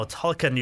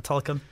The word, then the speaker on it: Tolkien: Now Tolkien, you mentioned there. That's the DVD uh, you got, uh, and it's also available on streaming. Just tell us about this, uh, Tolkien.